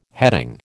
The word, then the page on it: heading: American English